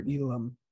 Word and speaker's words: Elam: Elam.